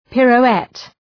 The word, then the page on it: pirouette: ,pıru:’et